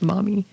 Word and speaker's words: Maumee: Maumee, pronounced Maumee.